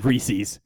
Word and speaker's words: Reese's: And they rhyme with species. That's right, everyone. We're talking Reese's.